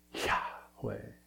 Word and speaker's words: Yahweh: Yahweh, also the name God gave to Moses at the burning bush, is to breathe. It's an exhale, right? You probably heard that. Yahweh. That's how it's said. Yahweh.